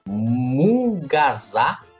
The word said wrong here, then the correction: munguzá: eu acho que se pronuncia assim. – Munguzá. – Munguzá, exatamente.